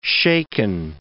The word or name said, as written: shaken